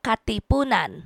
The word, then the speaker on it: Katipunan: The Katipunan.